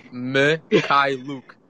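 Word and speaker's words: Mykhailiuk: Gosh, can you imagine how long it's going to take me to pronounce his last name on Mikhailuk? Hey, I'll teach you. Mikhailuk. – Mikhailuk. Mikhailuk. Mikhailuk. – It's not bad.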